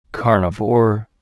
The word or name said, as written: carnivore